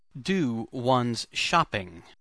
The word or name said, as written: do one's shopping